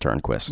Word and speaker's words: Turnquist: In all seriousness, his last name is pronounced Turnquist. – Yes, T-U-R-N-Q-U-I-S-T.